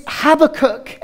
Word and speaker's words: Habakkuk: but it's Habakkuk.